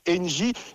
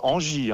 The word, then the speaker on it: Engie: Un seul mot, et c'est pour ça qu'on l'a choisi, un mot court, c'est donc Engie. Surtout ne pas prononcer Engie.